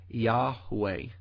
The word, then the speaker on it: Yahweh: And the pronunciation of these letters is debatable, but many Hebrew scholars, much more knowledgeable than myself, believe that these letters were originally pronounced something like Yahweh.